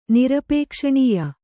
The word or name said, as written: ನಿರಪೇಕ್ಷಣೀಯ